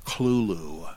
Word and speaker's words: Cthulhu: Joshi, says C-T-H-U-L-H-U as Clulu, semi-aspirated, with no T-H sound, because Lovecraft said that that's the way you're supposed to pronounce it.